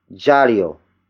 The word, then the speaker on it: diyaryo: And that is jaryo. Jaryo. Jaryo. Jaryo is spelled as D-I-Y-A-R-Y-O. It means newspaper, the direct object of this sentence. Remember that the D-I-Y letter combination is pronounced as J.